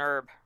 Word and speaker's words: herb: is an herb. – Oh, no, it's pronounced with a hard H.